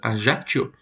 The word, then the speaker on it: Ajaccio: Como é que fala esse nome aí? Ajaccio? Ajaccio.